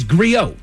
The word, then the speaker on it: griot: It's griot. G-R-I-O-T, griot. – I have to give you my guess. – The T is silent. – The T is silent.